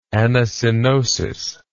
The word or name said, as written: anacoenosis